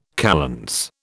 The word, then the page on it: kalends: British English